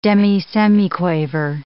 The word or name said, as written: demisemiquaver